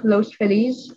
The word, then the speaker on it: Los Feliz: So the first one I read was, so it was called The Mysterious Haunting of Los Feliz.